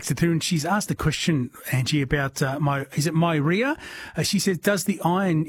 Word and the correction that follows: Meyer: So maybe a Meyer lemon, so M-E-Y-E-R. – That's it, yes. Excuse my bad pronunciation of that.